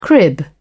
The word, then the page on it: crib: British English